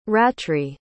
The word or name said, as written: ratri